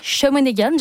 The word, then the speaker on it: Shawinigan: Ça s'appellera Shawinigan. Je le prononce comme ça, mais c'est comme ça que ça se prononce.